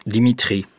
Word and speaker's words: Dimitri: Dimitri. – Ça s'écrit comment? – D-I-M-I-T-R-I.